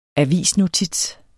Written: avisnotits

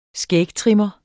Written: skægtrimmer

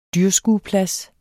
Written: dyrskueplads

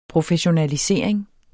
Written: professionalisering

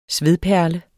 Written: svedperle

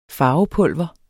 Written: farvepulver

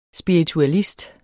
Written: spiritualist

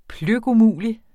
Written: pløkumulig

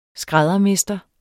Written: skræddermester

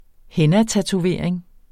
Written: hennatatovering

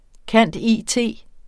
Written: cand.it.

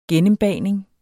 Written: gennembagning

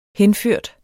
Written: henført

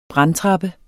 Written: brandtrappe

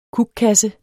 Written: kukkasse

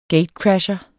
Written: gatecrasher

